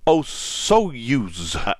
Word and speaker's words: Osoyoos: The desert in Canada is called Osoyoos. I hope I'm saying that correctly. It's O-S-O-Y-O-O-S. Osoyoos.